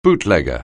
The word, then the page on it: bootlegger: anglais